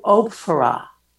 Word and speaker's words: Ophrah: Do you know how to pronounce it properly? – Moana? – No, O-P-H-R-A-H. – Oh, Ophrah. – Ophrah. – It's a biblical name.